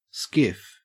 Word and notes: skiff: Australian English

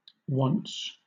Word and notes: wonts: British English

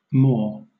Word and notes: maw: British English

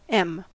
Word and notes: em: American English

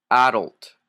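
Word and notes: adult: Canadian English

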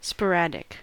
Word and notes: sporadic: American English

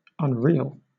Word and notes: unreal: British English

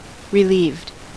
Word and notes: relieved: American English

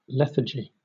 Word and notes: lethargy: British English

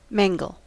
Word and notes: mangle: American English

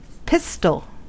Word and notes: pistol: American English

